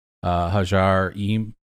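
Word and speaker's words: Ħaġar Qim: The first one is a decorated altar from Hajar Im.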